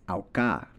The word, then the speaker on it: Auca: But a quick word you can pick up is Auca, pronounced like au and ka, auca, which is a friendly greeting in the Cocopá language.